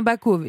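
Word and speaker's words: bacôve: Alors déjà, première question, qu'est-ce qu'une escute et qu'est-ce qu'un bacove? Est-ce que je le prononce bien? – C'est bien ça, c'est bien ça.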